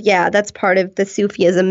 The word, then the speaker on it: Sufism: Now, I think I heard you say Sufism. Did I pronounce that correctly? And if so, you did.